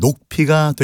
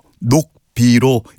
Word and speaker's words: 녹비: 여기서 말하는 녹비란 사슴 녹자의 가죽 핏자이므로 본래 발음대로 하면 녹피가 되겠죠. 그러나 사슴 가죽을 의미할 때는 관행적으로 녹비로 일컬어왔습니다.